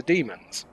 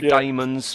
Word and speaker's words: daemons: Should we stick to? Because it's both in the story, isn't it? – John Pertwee does say daemons from the planet Deimos. – Yes. Okay, let's go with daemons then.